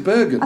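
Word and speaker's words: Burgon: think you're saying his name wrong. – Is it Bergen? – I think it's Bergen, yeah.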